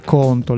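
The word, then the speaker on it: Qonto: Stiamo parlando di Conto. Con la Q con la Q ma si pronuncia tipo conto.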